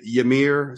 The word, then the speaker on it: Ymir: Finnish black metal band Ymir, so this is how you pronounce it, Y-M-I-R, and with their self-titled record, which I was very much a big fan of.